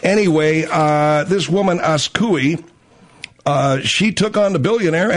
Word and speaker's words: Azcuy: Anyway, few people will take a billionaire head-on, but that's just what Masa Saidi Akukulu Walabatara, I don't know, her name is, like, spelled A-Z-C-U-Y. – A-Z. Spell it again. – A-Z. – A-Z. – C-U. – C-U. – Y. – Y. Asku. – Yeah. – Asku. – Yeah. – It's Askui. Askui.